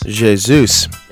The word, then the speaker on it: Jesus: And you would normally think in Spanish of Jesus, that's right. – But the Portuguese J is closer to the English J in that regard.